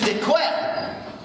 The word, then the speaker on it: Decuir: And I thought, I'm so glad my dad was not from Tennessee, because if my surname was spelled D-E-C-U-I-R, which is Dequer, but how would you pronounce it?